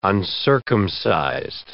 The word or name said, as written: uncircumcised